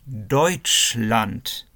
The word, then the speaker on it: Deutschland: Пишется D, E, U. Комбинация EU это для звука ой. Еще раз. D, E, U, T, S, C, H. И эта комбинация S, C, H это для звука Ш. Deutschland.